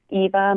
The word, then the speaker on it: Eva: So pretty much what I'm most active on right now is Instagram, and my Instagram name is at Eva Machado, and Eva is just EVA, and Machado is actually spelled like Machado, so MACHADO.